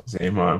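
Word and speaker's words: Xamot: Zaymont.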